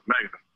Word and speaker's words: Mega: My name is Megan, and I'm calling from, right now I'm in Georgia.